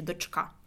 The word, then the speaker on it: дочка: І правильний наголос, до речі, дочка.